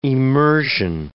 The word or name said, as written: emersion